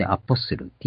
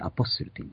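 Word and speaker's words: apostle: 英語でアポスル。 T が抜けて、発音から抜けてアポスルと言います。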